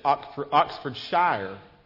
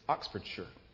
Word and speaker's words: Oxfordshire: And we did learn a lot while we were there, because the Sunday before we left, we spoke just briefly, and I pronounced it Oxfordshire. So I had no idea that that's not the way you pronounce it. It's Oxfordshire.